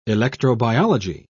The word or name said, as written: electrobiology